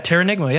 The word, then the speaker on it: Terranigma: Yeah, Terranigma, yeah, that's it.